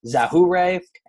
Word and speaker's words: Yajure: It's spelled Y-A-J-U-R-E, but it's pronounced, according to Baseball Reference, Zahure, and he was 0-0 with a 1.29 ERA in three games, and that is the extent of number 89 in baseball history.